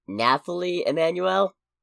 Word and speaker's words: Nathalie Emmanuel: Nathalie Emmanuel? – Oh, okay. – There's an H where I don't expect an H, and I'm an idiot.